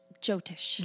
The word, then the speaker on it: Jyotish: Okay, am I saying this right? Is it Jyotish? Jotish. Jotish.